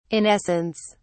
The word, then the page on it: in essence: British English